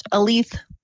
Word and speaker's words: Aleth: My name is pronounced Aleth, like a lethal weapon.